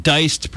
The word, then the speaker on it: Deist: It's J-E-F-F. And Deist, pronounced the German way, it's D like David, E-I-S-T, at Jeff Deist, if you want to have my jaundiced Twitter feed coming into your life.